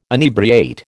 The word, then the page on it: inebriate: American English